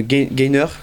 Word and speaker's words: gainer: Je sais qu'il y a des termes très spéciaux, on en a parlé comme gainer, je prononce bien, oui, ou whey.